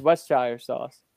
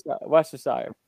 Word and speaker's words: Worcestershire: That's Westshire sauce. – Wow. – Worcestershire.